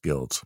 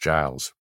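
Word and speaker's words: Guild's: That's not pronounced Guilds. It's pronounced Giles. – Is it really? – Yeah, it's Giles Lake.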